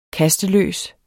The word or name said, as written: kasteløs